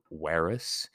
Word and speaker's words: verus: Ver, coming from verus. Remember, V's are pronounced as W's in Latin.